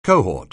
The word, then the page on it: cohort: anglais